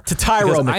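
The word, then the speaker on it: Tetairoa McMillan: T-Mac, Teratoa McMillan? I don't know how to pronounce it. I'll get there. – To Tyro McMillan.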